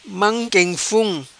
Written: Manquenfum

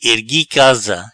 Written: Ergui casa

